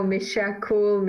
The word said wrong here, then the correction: Mishakal: It's Mishakal. – Too. – Mishakal. – Mishakal. – M-I-S-H-A-K-A-L.